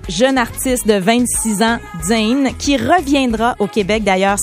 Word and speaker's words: Jain: J-A-I-N. – Mais en fait, on le prononce vraiment Jane.